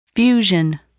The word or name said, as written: fusion